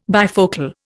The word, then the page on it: bifocal: Indian